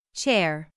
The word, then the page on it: chair: /ʧɛr/